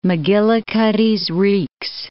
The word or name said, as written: Macgillicuddy's Reeks